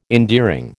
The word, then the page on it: endearing: American English